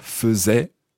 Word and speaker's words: faisait: That's from the verb « faire », but listen to the pronunciation. – Faisait. – Now, how's it spelled? – F-A-I-S-A-I-T. – Donc, en français, F-A-I-S-A-I-T. In English, F-A-I-S-A-I-T. So, A-I would normally be pronounced in French, of course, as « et », as in « faisait ».